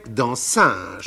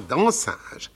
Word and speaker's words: dancing: On prononçait dansinge. – Ah, bah oui, c'est la, prononciation nogentaise. Naturellement, naturellement, dansinge. C'est donc dans ces dansinges que j'ai connu les airs de Christinet et de Scotto, qui sont devenus pour moi mon folklore chéri.